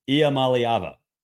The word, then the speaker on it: Iamaleava: Iamaleava.